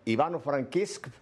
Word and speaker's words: Ivano-Frankivsk: Ivano Frankivsk, padre.